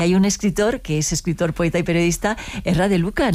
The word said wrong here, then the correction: Erri de Luca: ¿no? – Erri de Luca, sí, y van a ahí en los Alpes, a sí.